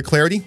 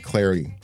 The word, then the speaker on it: Clardy: Follow me on Twitter at Troy Clarity. My last name is spelled C-L-A-R-D-Y, at Troy Clarity.